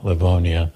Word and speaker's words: Livonia: Yeah, Livonia.